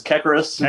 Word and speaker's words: Kekeris: Close enough. It's Kekeris. It's, you know, a weird Greek last name, but yeah, it's close enough.